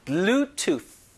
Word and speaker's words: Bluetooth: 자, 요 발음이 TH 때문에 블루투스 이렇게 하는 건 조금 영어에서 많이 벗어나고요. Bluetooth 하면서 혀끝을 윗니, 아랫니 사이에 넣으면서 공기를 막아야 돼요. 자, Bluetooth.